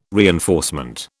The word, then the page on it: reinforcement: British English